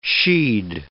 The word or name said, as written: she'd